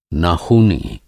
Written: नाख़ूनी

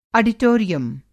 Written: आडिटोरियम

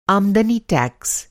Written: आमदनी टैक्स

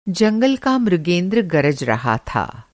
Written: मृगेंद्र

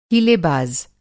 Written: हीलेबाज़

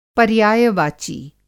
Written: पर्यायवाची

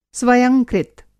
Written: स्वयंकृत

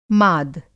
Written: माद

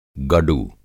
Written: गडु

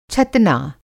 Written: छतना

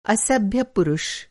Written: असभ्य

